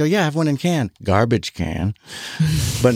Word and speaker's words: Cannes: When I was in Cannes and it is pronounced Cannes. – Yes. – I was like, Cannes. No, it's Cannes.